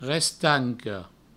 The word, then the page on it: restanca: restanco; Provençal